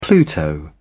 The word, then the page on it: pluto: British English